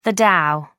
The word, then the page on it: dow: American English